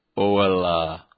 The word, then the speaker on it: Angela: Angela, pronounced Oella. Is that how it's pronounced in Germany? Anala. Oh, Anala. Oh, hella. Anala.